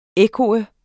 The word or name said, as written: ekko